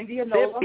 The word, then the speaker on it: Indianola: So tell the people, give up my audience an introduction to you and who you are, where you're from Indianola. I said that right, right? – Yes, Mississippi. – Indianola.